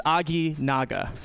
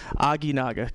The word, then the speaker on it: Aginaga: It's Aginaga. Aginaga. Kind of sounds Japanese, right?